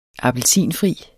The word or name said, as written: appelsinfri